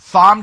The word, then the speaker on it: Thom: I'm going to put the H back in. No one pronounces the H. I'm pronouncing the H in the Marlo Show. Thom Tillis, cut 13, go.